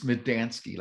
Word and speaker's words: Medansky: It's Medansky, like three words, me, Dan, and Sky.